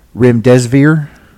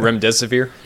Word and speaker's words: Remdesivir: The other drug study that everybody got excited about in the media, Remdesivir. I think I might have butchered that. – Remdesivir. – Yeah. – I was close. I heard it both ways.